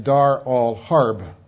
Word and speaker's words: Dar al-Harb: In Arabic, it's pronounced Dar al-Islam, house of peace, and Dar al-Harb, war.